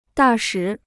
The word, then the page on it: 大使: dà shǐ